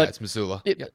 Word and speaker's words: Mazzulla: Mazzulla, right? Joe Mazzulla? Am I getting that correct? I'm probably butchering that. – I mean, you keep going. I'll look it up real quick. Yeah, but it's Mazzulla.